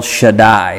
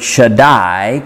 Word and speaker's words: Shaddai: The word Shaddai comes from a Hebrew word. We would pronounce it Shad or Shad, S-H-A-D.